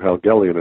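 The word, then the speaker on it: Helgelien: But yeah, Andrew Helgelian, and thank you, because I myself, not being of Norwegian heritage, was not quite sure how to pronounce it, but that sounds right.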